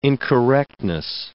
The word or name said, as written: incorrectness